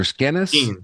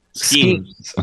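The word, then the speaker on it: Skenes: Everybody knows about, how do you say it, Skeens or Skennis? – Skeens. – Okay, everybody knows about Skeens.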